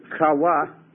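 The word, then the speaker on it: Chawa (חוה): The Hebrew is Chawa, C-H-A-W-A-H. C-H-A-W-A-H. The Hebrew pronunciation would be Chawa.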